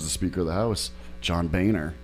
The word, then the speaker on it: Boehner: Boehner. – Yeah, exactly. Because Boehner, I don't see any of those letters in there. – It's like B-O-E-H-N-E-R.